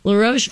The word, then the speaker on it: LaRoche: Is it LaRoche? How do you say that? – I believe that's right. – L-A-R-O-C-H-E? – Yeah, that's him. Mm-hmm. Yeah, oh, okay. LaRoche. You got it.